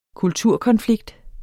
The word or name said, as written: kulturkonflikt